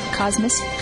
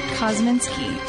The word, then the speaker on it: Kosminski: Cosmis. – Kosminski? – Kosminski.